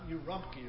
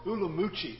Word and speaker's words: Urumqi: Urumqi, or Ulumqi is how it's said.